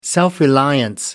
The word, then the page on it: self reliance: British English